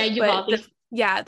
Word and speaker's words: Uvalde: And if there is a GoFundMe for the Uvalde, I don't know if I said that right. – Oh, yeah, Uvalde.